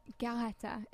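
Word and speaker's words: Garréta: Anne Galletta, if you want to pronounce it with the accent.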